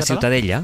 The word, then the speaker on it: Ciutadella: Para mí la Ciutadella es... ¿cómo se pronuncia? – La Ciutadella. – Ciutadella.